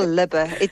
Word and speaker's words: Lube: It sounds very exotic when you say it like that, but it's just a very clear, simple Lube. There's nothing grand about that.